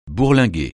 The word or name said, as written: bourlinguer